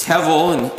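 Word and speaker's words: hevel (הבל): It's hevel in Hebrew, sometimes spelled H-E-B-E-L, pronounced more like H-E-V-E-L.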